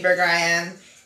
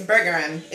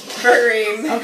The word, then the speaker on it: Burgerim: It says, I said, how do you say Burger I Am? And it's the name Burger, it's Burgerim. It's Hebrew for many burgers. – Oh. – And it's traditionally pronounced Burgerim.